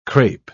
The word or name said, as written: crepe